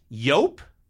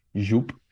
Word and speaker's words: Yope: You have worked for the company that manufactures Jupe, and you're saying that Tracy Morgan and I are mispronouncing this, and it's Yope? – Yes.